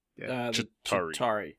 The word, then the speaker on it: Chitauri: Did I say that right? – Chitauri. – Chitauri. Yeah. I was close.